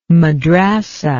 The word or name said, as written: madrasah